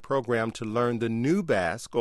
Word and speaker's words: Basque: My first language is it pronounced Basque? – Basque, yeah.